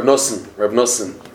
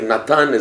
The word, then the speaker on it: Nosson: It's in normal Hebrew pronunciation, you call him Rabbi Nathan. But in Breslov, affectionately, even by Sfardim, they say Rav Nosson. Okay, Natan is Nosson.